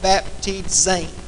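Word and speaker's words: baptizein: Now, those two little strange words right there are pronounced it should be baptizo and baptizain.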